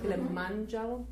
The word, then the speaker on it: Kilimanjaro: Kilimanjaro.